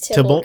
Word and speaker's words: Tybalt: It's Tybalt because it has no Y.